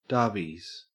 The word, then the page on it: darbies: Australian English